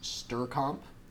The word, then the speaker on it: strcmp: There are other ones that are also in the string library, such as strcomp, which is spelled S-T-R-C-M-P, which you use to compare strings to each other.